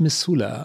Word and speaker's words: Missoula: Wie, wie spricht man das aus? Missoula. – Missoula.